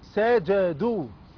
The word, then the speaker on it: سجدوا: طب الكلمة اللي بعدها سا. – سا. – جا. – جا. – سجا. – سجا. – دو. – دو. – سجدوا. – سجدوا.